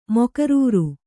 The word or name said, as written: ಮೊಕರರು